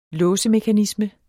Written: låsemekanisme

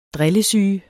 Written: drillesyge

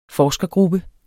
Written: forskergruppe

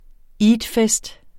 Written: eidfest

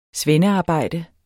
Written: svendearbejde

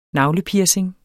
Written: navlepiercing

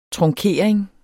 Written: trunkering

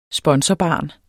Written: sponsorbarn

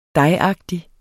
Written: dejagtig

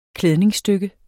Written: klædningsstykke

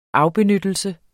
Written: afbenyttelse

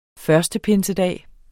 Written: pinsedag

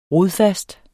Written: RODFASTE

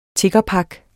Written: tiggerpak

